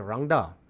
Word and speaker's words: rangda: It's not rendang. – I didn't say rendang. I said rangda.